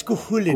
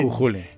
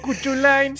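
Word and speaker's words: Cú Chulainn: No es Cthulhu, es Cujulín. – Cujulín. – Se escribe Cuchulain, pero no lo voy a decir así porque suena de la verga. – Suena muy bonito. Cuchulain.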